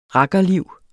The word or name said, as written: RAKKERLIV